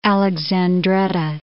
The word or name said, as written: Alexandretta